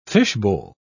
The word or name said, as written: fishbowl